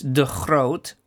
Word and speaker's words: De Groot: So just for information and for the listeners, my surname is De Groot. The Dutch pronunciation is De Groot,